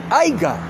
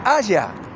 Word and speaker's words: Haya: No sabe que se dice Haya. Dice Aiga en todos sus discursos. O sea, ¿y dónde está el asesor que le dice, no, no, pero pará? No se dice Aiga, se dice Haya, bruto, burro.